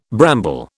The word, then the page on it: bramble: British English